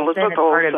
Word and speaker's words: Elizabeth Olsen: Elizabeth Olsen.